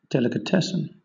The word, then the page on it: delicatessen: British English